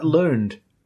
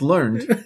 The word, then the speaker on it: learned: Wagon wheel is quite learned. No, it's pronounced learned.